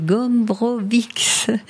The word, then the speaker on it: Gombrowicz: C'est Witold, alors, pour bien le prononcer, Gombrowicz, qui a dit ceci.